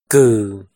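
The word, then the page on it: Kwm: Hmong; last name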